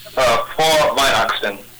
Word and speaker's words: Frohe Weihnachten: Frohe Weihnachten. – Frohe Weihnachten? – Frohe Weihnachten. – Frohe Weihnachten. – The R is, it's sort of between a roll, like a Spanish R, and a more guttural R, so it's Frohe.